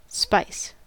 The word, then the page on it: spice: American English